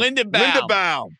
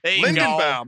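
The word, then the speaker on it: Linderbaum: Lindenbaum. – Lindenbaum.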